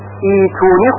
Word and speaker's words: ائتونی: اما اگر دقت داشته باشید هیچ کدام از قراء این کلمه رو با دو همزه نخوندن ائتونی، بلکه ایتونی خونده شده، همه قراء اون رو ایتونی خوندن.